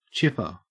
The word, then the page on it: chipper: Australian English